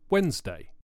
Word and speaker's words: Wednesday: The word Wednesday is difficult because it has a silent letter in the middle of it, the D.